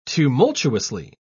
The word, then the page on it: tumultuously: British English